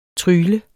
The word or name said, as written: trygle